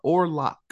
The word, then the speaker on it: Orlok: And so Dexter's search leads him to I guess she's a music star, and her name is Marissa Orlok. as the bad guy says. He puts like so much emphasis on the K.